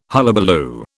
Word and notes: hullabaloo: British English

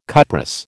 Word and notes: cuprous: American English

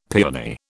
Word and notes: piani: British English

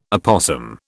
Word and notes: opossum: British English

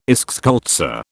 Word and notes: eschscholtzia: British English

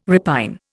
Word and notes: repine: Indian

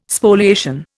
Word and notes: Spoliation: Indian

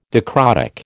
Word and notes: dicrotic: American English

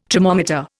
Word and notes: tromometer: Indian

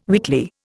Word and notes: weakly: Indian

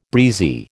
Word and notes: breezy: American English

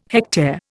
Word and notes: hectare: Indian

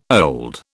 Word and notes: old: British English

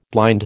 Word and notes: blind: American English

